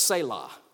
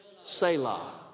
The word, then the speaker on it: Selah: That's how you say it, Selah. Would you say that with me? Selah.